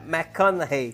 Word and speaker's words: McConaughey: Matthew McConaughey. – É, McConaughey.